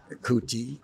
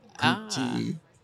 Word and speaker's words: Kuti: Cootie, Kante, Cootie, K-U-T-I.